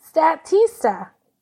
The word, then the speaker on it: Statista: You're close enough. – Statista. – Like statistics, Statista.